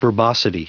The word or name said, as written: verbosity